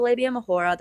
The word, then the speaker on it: labia majora: And eventually, like at the very end of the presentation, the professor chimed in and was like, just letting you know, it's pronounced labia majora. Turns out this guy thinks that it is in Spanish, hence the Mahora.